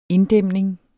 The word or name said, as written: inddæmning